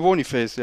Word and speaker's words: Boniface: Wird der so ausgesprochen? Ja. – Der wird so ausgesprochen. Victor Boniface, ja. Nein! – Ich habe es mir vorher auch nochmal, ich wusste jetzt nicht mehr, ob der irgendwie Bonifaz oder, oder wie auch immer ausgesprochen wird,